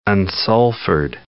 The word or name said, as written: unsulfured